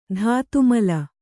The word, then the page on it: ಧಾತುಮಲ: dhātu mla